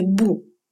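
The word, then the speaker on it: бу (不): Следующий вид модуляции – это модуляция отрицательной частички «бу». Отрицание «бу» произносится четвертым тоном. Но если после «бу» следует слог с четвертым тоном, в таком случае «бу» читается вторым тоном.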